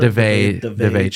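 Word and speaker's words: Daveigh: Devella? – DeVay Chase. – DeVay Chase, mm-hmm.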